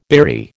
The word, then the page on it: beery: American English